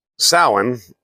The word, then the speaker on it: Samhain: Now, it's spelled S-A-M-H-A-I-N, but it says it's pronounced Samhain. S-O-W-E-N.